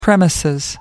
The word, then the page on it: premises: American English